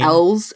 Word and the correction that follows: Elwes: How do you say his last name actually? Elwes. – Yeah, Carrie Elwes.